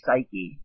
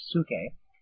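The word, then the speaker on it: psuche (ψυχή): So, psuche here, I used to say psyche, but I believe it's pronounced psuche.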